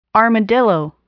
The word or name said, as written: armadillo